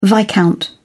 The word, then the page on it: viscount: British English